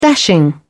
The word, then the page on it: dashing: British English